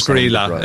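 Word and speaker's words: Grýla: It is Grilla. It is Grilla. That's Grilla. The only one I know the pronunciation for.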